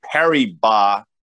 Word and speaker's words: Paribas: It's worth noting, Dan, that BNP Paribas, I just like saying that because, you know, the S is silent, as you know, because you work for one of those fancy places.